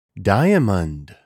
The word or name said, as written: Diamond